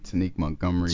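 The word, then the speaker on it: Tanek Montgomery: Just punch in my name, Tanique Montgomery. – Tanique Montgomery. – How do you spell that? – T-A-N-E-K Montgomery.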